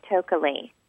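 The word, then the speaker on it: Tokeli: Hi, this is Tokalee. I'm calling from Coronado in California. – Tokalee, what a great name. How do you spell that? – It's T-O-K-E-L-I, Tokalee.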